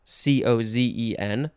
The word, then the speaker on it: cozen: Cozen, spelled C O Z E N, like dozen, but I think it's pronounced cozen.